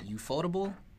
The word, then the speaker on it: Ufotable: Ufotable. – Uh, I think it's like UFO table. UFO but table.